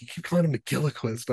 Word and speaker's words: McGilchrist: I think McGilchrist has, did I say it right? – McGilchrist. – I always add a Gila in there because I think that's every Irish name.